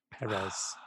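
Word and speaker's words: Pérez: Perez.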